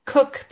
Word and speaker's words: Koch: The recent study that I can't remember the last, her last name is Cook, but it's spelled K-O-C-H, so the screen reader says it wrong.